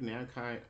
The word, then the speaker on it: Namco: He actually worked on a few Namco, uh, Namco Bandai fighting games. – For you said it right the first time, Namco.